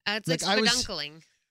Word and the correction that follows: spelunking: What's the word? – Spelunking. – Spelunking. Close enough.